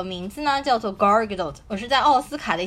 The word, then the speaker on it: Gal Gadot: 但是大家知道高尔加朵的英文名字怎么念吗？叫 Gargadot，Gargadot，是不是听上去和女神完全不搭边？所以我觉得能把 Gargadot 翻成盖尔加朵真的很不容易。